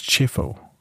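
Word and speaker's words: Chieffo: Ich glaube, sie heißt Shivo. Echt? Wir haben sie immer Kevo genannt, weil ich gedacht habe, das wird italienisch ausgesprochen, aber ich glaube, es wird amerikanisch ausgesprochen. Das ist Chifo.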